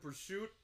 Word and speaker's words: prosciutto: We were just talking prosciutto. – About prosciutto. Prosciutto. Grilled trout and banana foster, which I don't know. – You have a little bit of honey, Kolsch, and prosciutto.